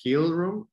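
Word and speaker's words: Heal Room: En Instagram están como Hill Room, Hill raya piso room y Hill se escribe H-E-A-L rayita piso R-O-O-M.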